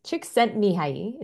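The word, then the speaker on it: Csikszentmihalyi: Now, Chick Mihai, I think is how you say his name. Chick Sent Mihai, this is how you say his name.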